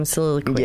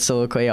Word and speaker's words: Soliloquy: This was actually the rhyme that I did with Pheromonch, and it rhymes with soliloquy, obviously. So it goes, soliloquy has the ability to spit acidity with agility additionally.